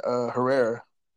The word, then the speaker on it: Herrera: It's just like it's spelled out, man. Herrera.